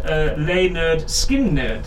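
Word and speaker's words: Lynyrd Skynyrd: This is from the album pronounced Leonard Skinner.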